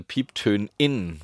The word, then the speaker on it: Pieptönin: Ah, eine Pieptönin. Man spricht das Binnen-I ja sozusagen mit. Durch eine kurze Zäsur. – Durch eine kurze Zäsur spricht man das I mit.